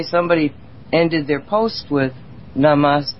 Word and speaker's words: Namaste: Well, they always pronounce it Namaste. – Namaste. – Like it ends in, imagine the letter ends in A-Y, so they always say Namaste.